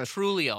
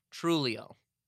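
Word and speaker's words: Trujillo: Trulio. No. Trulio, I believe that's the name. T-R-U-J. Trulio, right? Grizel Trulio. Either way, Eater number 5 would like to say to Grizel Trulio, his girlfriend, he's sorry for being a dumbass boyfriend.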